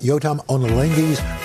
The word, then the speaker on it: Yotam Ottolenghi's: Do not miss Chef Yotam Ottolenghi's latest cookbook, Simple 2.